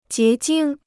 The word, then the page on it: 结晶: jié jīng